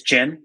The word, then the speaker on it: Ginn: It's Ginn, like the drink.